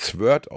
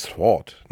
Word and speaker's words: Sword: Also, ich habe früher mal das Schwert auf Englisch mit Sword ausgesprochen, aber es heißt Sword, ne? Sword.